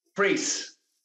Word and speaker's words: Priest: Priest. – It's just Priest. – Yeah, like priest, but without the T on the end.